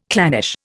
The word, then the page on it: clannish: Indian